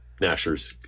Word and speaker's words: gnashers: yeah, Nashers. Like, well, okay. No, like GN is, the right. G is silent anyways.